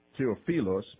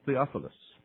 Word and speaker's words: Theophilus: Really, it's Theophilus, but I couldn't say it that way, so I have to say it like you guys would say it here, Theophilus.